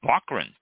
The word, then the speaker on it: Lofgren: And Lofgren, thank you. – I wrote the pronunciation like phonetically, and it's L-O-C-K. Mm. It's not how you spell it, but it's how that's how you pronounce it.